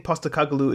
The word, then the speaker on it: Postecoglou: I think Postecoglou is taking or is looking. I said it right the first time. I think Postecoglou is taking a longer view,